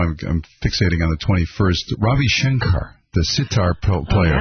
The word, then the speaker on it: Ravi Shankar: Norah Jones, the singer, is Ravi Shankar.